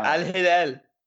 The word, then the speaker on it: Al Hilal: Sebastian Giovinco leaving for Al Hilal. Did I say that right? – Yeah, Al Hilal. Come on, man. You got to throw your accent in there.